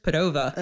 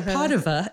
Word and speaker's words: Padova: Where's Padova? – Uh-huh. – That's the way the Americans say it. But Padova is the way the Italians say it.